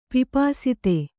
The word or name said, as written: ಪಿಪಾಸಿತೆ